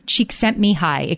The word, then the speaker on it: Csikszentmihalyi: His name is Mikhail Csikszentmihalyi.